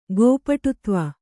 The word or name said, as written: ಗೋಪಟುತ್ವ